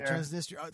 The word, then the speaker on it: Transnistria: They're part of a breakaway state, Transnistria, I think is how you pronounce it. – Transnistria.